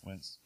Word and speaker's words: Wentz: Wentz.